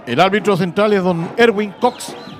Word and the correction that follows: Cook: El árbitro se pronuncia tal cual como se pronuncia cocinar en inglés. Cook.